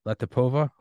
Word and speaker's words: Latypova: Latypova, hopefully I pronounced that correctly.